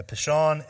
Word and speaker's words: Pishon: But where is this Pishon River? Did I say that right? Yeah, Pishon and the Gihon rivers.